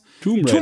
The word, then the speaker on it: Tomb Raider: Dann haben wir noch ähm, to, to, Tom Raider. To, wie, wie spricht man das nochmal aus? – Tomb Raider. Tomb Raider.